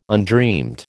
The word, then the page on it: undreamed: American English